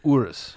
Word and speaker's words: Urus: How do you pronounce the Urus? – Urus. – I say Urus.